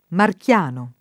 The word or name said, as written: marchiano